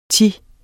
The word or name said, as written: thi